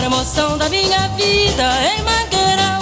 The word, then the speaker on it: Mangueira: Que te decía, ves que es, se escribe Mangueira. Debería pronunciarse Mangueira. Como, pero me explicabas tú que allí la I se la comen directamente. – Sí, los brasileños la comemos ahí.